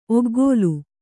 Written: ಒಗ್ಗೋಲು

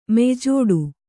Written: ಮೆಯ್ಜೋಡು

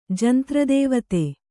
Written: ಜಂತ್ರದೇವತೆ

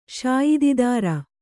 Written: ಶಾಯಿದಿದಾರ

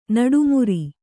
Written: ನಡುಮುರಿ

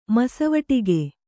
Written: ಮಸವಟಿಗೆ

